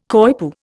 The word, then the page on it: coypu: Indian